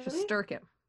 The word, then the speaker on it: Shesterkin: Shesterkin? – Putting the emphasis on the wrong syllable. – Really? – Shesterkin. – Oh, Shesterkin.